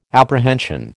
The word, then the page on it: apprehension: American English